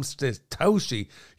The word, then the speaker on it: Satoshi: If you like what we're doing, you can pay us out in some Satoshi.